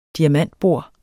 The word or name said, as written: diamantbor